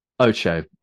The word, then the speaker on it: OTXO: Ocho. Ocho. – Ah, yes. – But it's spelled as OTXO.